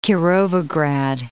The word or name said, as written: Kirovograd